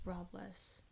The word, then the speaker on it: Robles: That's Robles. – Oh. – Sorry, I pronounced it wrong. – That's okay. – I got the L mixed up where it's supposed to be.